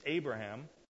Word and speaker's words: Avraham: The meaning of those names is Avraham, if you want to say it properly, all right, is the father of many.